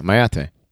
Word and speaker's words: mayate: Yeah, mayate. That's proper. – Mayate. – Mayate. – It's M-A-Y. A-T-E.